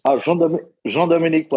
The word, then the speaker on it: Jean Dominique: Jean Dominique was perfect.